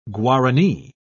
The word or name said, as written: guarani